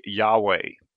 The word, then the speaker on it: Yahweh: And generally, the understanding among scholars is that the most likely pronunciation of this would be Yahweh.